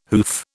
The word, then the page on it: hoof: British English